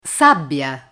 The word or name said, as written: sabbia